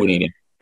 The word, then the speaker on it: Grenadian: Grenadian.